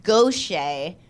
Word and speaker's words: Gauthier: It looks like Mary Gauthier or something like that, but it's pronounced Gaucher. Because I think it's like, ugh, Cajun or something.